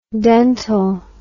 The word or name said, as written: dentel